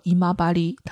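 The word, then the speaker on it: 今治: 精致在日语当中是读作姨妈巴黎，它的毛巾就是姨妈巴黎套路。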